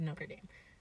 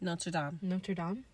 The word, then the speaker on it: Notre Dame: My entire middle school experience, when we were talking about, oh my god, what high school you're going to, everybody who was going to Notre Dame said Notre Dame. But it's, how do you say it? – Notre Dame. That's French.